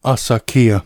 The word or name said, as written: Axarquía